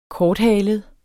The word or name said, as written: korthalet